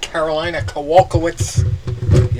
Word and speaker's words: Kowalkiewicz: We got Carolina Kowalkiewicz.